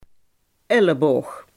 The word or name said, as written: elleboog